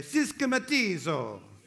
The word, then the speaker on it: systematizo: You've got to say it like an Italian, not a Greek, okay? It's a Greek word, but I can't say it like a Greek. So everyone say systematizo.